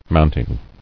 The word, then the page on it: Mounting: mount·ing